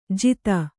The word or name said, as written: ಜೀತ